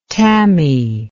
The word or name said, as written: tamis